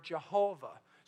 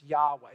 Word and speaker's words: Yahweh: Some people add a J sound into it and get the word Jehovah. So you've heard Jehovah God. Others say, well, there is no J sound in Hebrew, so we're going to stick with the Yahweh. That's how they see it. In fact, most scholars say that is the best way to pronounce these four consonants, Yahweh.